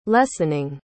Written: lessening

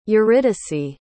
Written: euridice

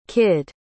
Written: kidd

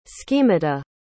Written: schemata

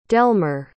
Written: delmer

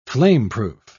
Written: flameproof

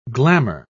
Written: glamour

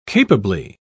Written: capably

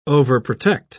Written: overprotect

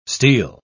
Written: steal

